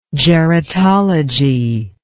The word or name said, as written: geratologous